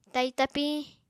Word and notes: tai tapiñ: apatani

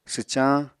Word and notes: swcañ: apatani

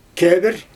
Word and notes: kebr: Xyzyl

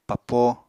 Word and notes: papo: Apatani

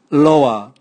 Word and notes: loa: Raga

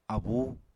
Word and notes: abú: Apatani

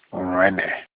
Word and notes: urene: Olukumi

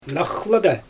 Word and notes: naxlyda: Xyzyl

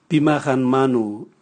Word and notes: bima ganmanu: Raga

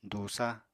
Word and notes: dùusa: Apatani

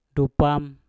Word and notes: dupam: Mpur

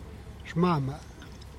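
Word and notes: xmama: Teotitlán del Valle Zapotec

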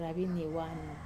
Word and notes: ravinie wana: wamesa

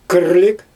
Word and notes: kyrlig: Xyzyl

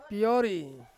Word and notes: piori: Ansus

